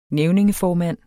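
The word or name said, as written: nævningeformand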